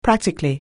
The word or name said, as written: practically